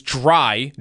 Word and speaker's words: Drai: There's Dry, D-R-A-I, and then there's... – Dr. Dre. – Dr. Dre, also spelled D-R-A-I. But I bet that one's pronounced Dre, I would assume.